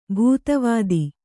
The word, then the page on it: ಭೂತವತಿ: Kannada